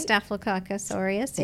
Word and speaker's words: Staphylococcus aureus: We know for sure that the bacteria that causes or is associated with acne, Staphylococcus aureus, did mm-hmm. I say that right? – Staphylococcus aureus, yeah.